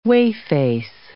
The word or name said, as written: whey-face